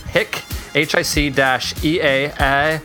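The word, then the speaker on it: Hiketeia: And the author, I think it's Greg Rutka, said that it was pronounced Hick, Hic-Eateia, but we'll probably say it wrong 5,000 different ways because we've already had the argument about GIF versus JIF versus whatever.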